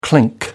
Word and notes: clink: American English

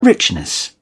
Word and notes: richness: British English

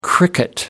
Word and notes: cricket: American English

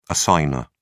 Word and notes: assignor: British English

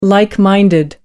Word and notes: like-minded: American English

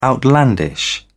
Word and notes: outlandish: British English

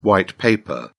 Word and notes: white paper: British English

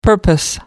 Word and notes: purpose: American English

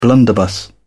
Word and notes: blunderbuss: British English

